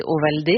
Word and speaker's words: Ovaldé: Ouais, mais parce qu'en fait, en français, si vous écrivez, ça s'écrit O-V-A-L-D-E, tout le monde prononce ça Ovalde. – Et c'est pas bien ça n'a pas tout à fait quand même la même musique. Et mon père m'a toujours dit qu'on s'appelait Ovaldé,